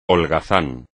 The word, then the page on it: holgazán: espagnol